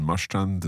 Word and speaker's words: Marstrand: We've up in Marstrand and Klädesholmen.